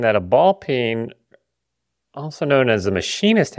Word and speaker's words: ball peen: And You're looking for the ball peen that's in the aisle right next to the cock. – Ball. – Is that how am I pronouncing it wrong? How do you say it? – Ball peen.